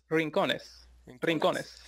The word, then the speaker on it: Rincones: And then your last name is Rincon or Rincones? How do you pronounce it? – Rincones.